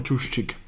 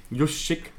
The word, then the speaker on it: Juszczyk: Also George Kittle kann blocken, Emmanuel Sanders, Deebo Samuel, Juszczyk, ihr Fullback. Ich hoffe, ich habe den Namen richtig ausgesprochen. – Juszczyk. – Juszczyk.